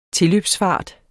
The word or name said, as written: tilløbsfart